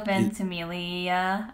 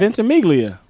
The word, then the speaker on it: Ventimiglia: Milo Ventimiglia. I think the G silent. – Ventimiglia.